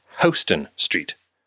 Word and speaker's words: Houston: I learned the hard way that while the street name is spelled the same way as Houston, as in the city of, if you don't want to look like some know-nothing tourist, it is in fact pronounced Houston Street.